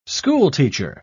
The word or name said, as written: schoolteacher